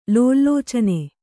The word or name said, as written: ಲೋಲಲೋಚನೆ